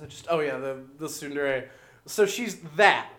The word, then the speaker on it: tsundere: Is it tsundere? – Tsundere.